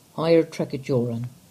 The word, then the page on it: Higher Tregajorran: English